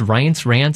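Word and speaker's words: Reince: I can never pronounce his first name. It's Rance.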